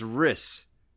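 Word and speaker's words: Rhys: It's Riss.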